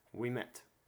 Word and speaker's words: Ouimet: I believe O-U-I in French is we. So O-U-I-M-E-T. – It's Francis Ouimet. – We met. As in, we met.